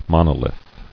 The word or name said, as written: Monolith